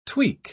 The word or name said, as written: tweak